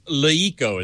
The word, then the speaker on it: LeEco: Leeco is how it's pronounced, yeah. It's L. Weird. You tell me, kids. L-E-E-C-O. It sounds like Leeco to me.